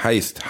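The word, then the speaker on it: Heist: Ich meine den großen Banküberfall, Hießt. – Ja. Ah. Hießt, heißt, heißt wird es ausgesprochen, heißt.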